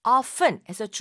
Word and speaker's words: often: 이걸 often에서 중간에 오는 T를 묵음으로 발음을 하지 않고 often 하고 말하는 경우도 있고 often 해서 T를 또 굳이 꼭 말하는 사람도 아주 많이 있습니다. 그래서 often 둘 다 아무 상관이 없는데요.